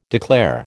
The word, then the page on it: declare: American English